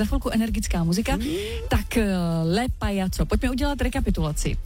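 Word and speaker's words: Payaco: Píše se to Pa-Y a co a čte se to Pajaco. – Pajaco. – V závorce napsáno opravdu s C.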